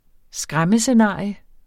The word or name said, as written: skræmmescenarie